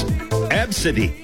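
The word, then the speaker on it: ABCDE: It's pronounced, according to this, as Absidy. Absidy.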